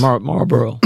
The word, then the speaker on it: Marlboro: But we're talking about the makers of Marlboro, is that how you say it? – Cigarettes? Yes, Marlboro.